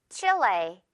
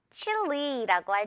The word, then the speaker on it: Chile: 자, 이거 발음은요. 두 가지가 가능해요. Chile. 이렇게 우리가 말하듯이 말할 때도 있고요. Chile라고 할 때도 있어요. Chile, Chile 둘 다 여러분 들을 수 있을 겁니다.